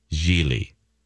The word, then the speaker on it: Gigli: G-I-G-L-I, Geely.